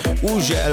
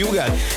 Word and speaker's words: Hugel: Így van, az úriember, akinek minden országon másképp ejtik a nevét, valahol Uzsel, van, ahol Hügel, van, ahol Ugel, meg mindenféle kép, de nálunk úgy mondjuk, hogy Hugel, és Szálltó, és a Jamaican Bam Bam érkezik, csavarjátok fel a hangerőt!